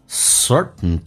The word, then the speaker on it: certain: Certain darkness, T 跟 N 在美式发音连在一起的时候， T 可以轻轻的去流形成阻塞，似听到没有听到，变成 certain。